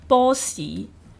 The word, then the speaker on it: 波士: 就是广东话的发音是波喜跟许仔，